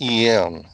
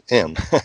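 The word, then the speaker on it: EM: And mother is E-M, it's M. E-M, that's how you pronounce it.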